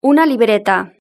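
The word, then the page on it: una libreta: Spanish (Spain)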